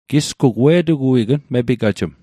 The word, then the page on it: upmepigaj: Mi'gmaq